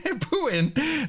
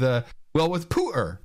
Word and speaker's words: Putin: Except for her pronunciation of Putin. – Putin. Oh, we'd have to work on the Putin thing. Listen to her. This is her with Pooter.